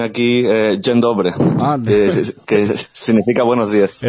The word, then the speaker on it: dzień dobry: Eso te iba a decir, yo lo tenía por aquí apuntado, pero no sabía cómo se pronunciaba ese el polaco. – Dzień dobry, sí.